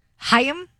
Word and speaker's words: Haim: Fans of, I always thought it was Haim, but it's Haim. – Haim? – Yeah, Haim. – Haim. – Okay. They say it's two syllables. Haim.